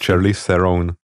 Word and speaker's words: Charlize Theron: Charlize, wie spricht man es echt aus? – Also sie heisst Charlize Theron.